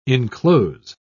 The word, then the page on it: inclose: British English